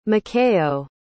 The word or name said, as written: mikayo